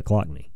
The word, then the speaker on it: Occoquan: Usually, I'll just say Occoquan. – There you go. So it's almost like the O's not there. – Occoquan.